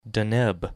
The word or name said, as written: Deneb